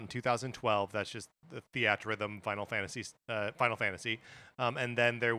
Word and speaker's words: Theatrhythm: I want to stop us at the pronunciation of Theater Rhythm or Theatrhythm. The announcer in the direct said Theater Rhythm, which I guess makes sense.